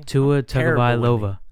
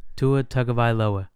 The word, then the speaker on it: Tua Tagovailoa: Tua Tagovailoa. Tua Tagovailoa.